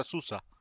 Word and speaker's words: Sousa: No sé, porque Sousa en realidad, nosotros lo pronunciamos Sousa, en realidad se pronuncia Susa.